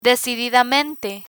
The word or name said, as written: decididamente